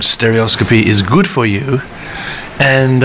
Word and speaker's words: stereoscopy: I am with Brian May at Proud Gallery, Central London, um, for the stereoscopy 3D photography exhibition.